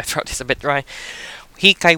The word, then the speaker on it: hikay: We call it hikay.